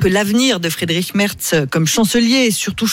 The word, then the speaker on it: Friedrich: J'espère que je le prononce bien, Friedrich. – Oui, ouais, Friedrich.